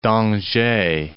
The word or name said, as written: David d'Angers